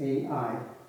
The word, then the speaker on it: Ai: I'm going to pronounce it Ai. It's Ai.